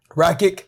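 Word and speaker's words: Rakić: How do you say his name? – Rakic.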